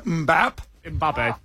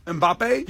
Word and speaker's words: Mbappé: Kylian Mbappé. – Mbappe.